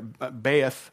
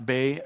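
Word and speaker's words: Beth (בית): And that word house in the Hebrew there, Beth, I've also heard it pronounced Bay, but Beth, it means household, it means family.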